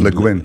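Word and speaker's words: Le Guin: Levin, Leguin.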